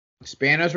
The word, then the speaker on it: Spanos: Spanos rhymes with Thanos.